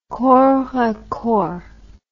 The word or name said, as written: corps à corps